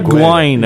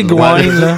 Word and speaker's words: Gwynne: C'est pas Fred Gwynn. – Mais comment tu le prononces? – J'ai essayé de pas rire, là. Mais... – Non, ben, je t'entends. – Comment tu le prononces? – Ben, Fred Essaye, vas-y. – Fred Gwynne. On va dire Gwynne.